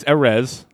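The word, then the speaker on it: Erez: So it's Arez.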